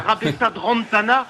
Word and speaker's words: Rabesandratana: J'ai beaucoup appris à RTL avec toi, notamment à prononcer correctement Eric Rabesandratana. – Oui, je me souviens. – Rabesandratana. – Je te tapais sur les doigts, effectivement. – Ah, mais oui. – Parce que tu le prononçais mal.